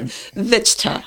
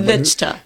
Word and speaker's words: Vichtcha: Kong, did I pronounce that correctly? – Close, Teddy, close. – All right. – Vichtcha. – Vichtcha. All right, yes.